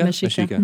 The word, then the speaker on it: Mexica: Is that how you say it? Mexica? – Yeah, Mexica. Mexica.